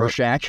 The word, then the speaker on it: Rorschach: You know what I'm talking Rorschach.